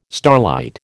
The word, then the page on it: starlight: American English